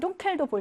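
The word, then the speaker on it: dunkel: 4번 둥켈도 볼게. 강세 있는 모음 뒤에 자음 하나, 둘. 두개 있네. 그러니까 짧게. 얘는 안 예외야. 얘는 법칙대로 짧은 우.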